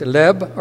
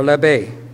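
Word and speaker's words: leb (לב): In Hebrew, it's leb or lebe, L-E-B-E.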